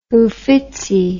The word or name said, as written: Uffizi